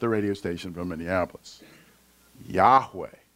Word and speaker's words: Yahweh: And from the study of this and also the structure of the Hebrew language, most scholars today believe that Y-H-W-H is pronounced as Yahweh.